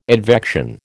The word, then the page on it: advection: American English